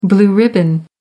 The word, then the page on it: blue ribbon: American English